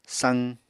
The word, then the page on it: saq: apatani